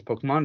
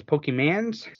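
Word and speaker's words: Pokémon: I mean, they didn't even pronounce it as Pokemon. They always pronounce it as Pokemans.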